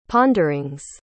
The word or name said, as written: ponderings